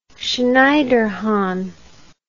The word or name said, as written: schneiderhan